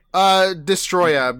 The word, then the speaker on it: Destoroyah: Destoroyah, because it was supposed to be Destroyer, but that word was copyrighted by a band. – Oh, okay. So we have the number one seed, Godzilla versus Destoroyah, which came out in 1995.